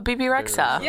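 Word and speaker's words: Bebe Rexha: And then earlier this year, I don't know how to say her name. Baby Rexha? – Oh, BB Rexha.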